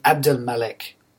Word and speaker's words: Abdelmalek: My last name is spelled M-A-L-I-K. It used to be Abdelmalek. A-B-D-E-L-M-A-L-E-K.